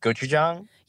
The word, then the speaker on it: gochujang: And I'm just curious, do you have anything that's got gojing gojing on it, like a gojing th- sauce? – Oh, like like gochujang?